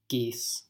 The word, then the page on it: geese: American English